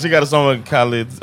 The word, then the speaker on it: Khalid: And I haven't been really digging her music that much lately, but she has a song with Khalid that, how do you say his name? Is it Khalid? It's Khalid, right? – Khalid.